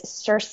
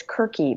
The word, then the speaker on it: Circe: And I may be pronouncing that wrong. I don't, like, my ancient Greek pronunciation is not perfect, probably. Maybe it's Kirky, maybe it's Circe, but it's C-I-R-C-E. – And I think the Game of Thrones pronunciation is acceptable to most people.